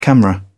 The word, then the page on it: camera: British English